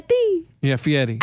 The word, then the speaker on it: Fieri: Fieri. Yeah, Fieri. – I don't like that his name's spelled with an R because it throws me off every time.